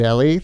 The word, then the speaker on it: Elle: Evan, and I think I was saying your name wrong, is it Ellie? – Ellie. – E-L-L-E, but Ellie. Evan and Ellie, thanks for joining us.